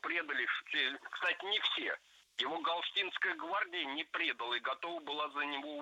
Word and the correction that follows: предала: Предала, неверно, правильно, предала. Ударение должно падать опять же на окончание.